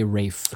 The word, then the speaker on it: Ralph: It's spelled Ralph, but you say Rafe. – Okay, Rafe Fiennes.